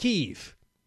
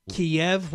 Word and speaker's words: Kyiv: They've also bombed a shopping mall in Kyiv, which, by the way, over the weekend, I learned, John, why it's pronounced Kyiv. Kiev was the Russian pronunciation. The Ukrainian language, it's a little different than it comes out more Kyiv.